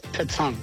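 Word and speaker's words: pétanque: Okay, not petonk, petonk. – It's pronounced petonk. – Petonk? Oh, you hit a K sound there.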